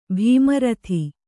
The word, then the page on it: ಭೀಮರಥಿ: bhīma rathi